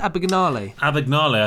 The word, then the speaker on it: Abagnale: How do you pronounce it? Abagnale, I think, is how they pronounce it in the film Catch Me If You Can.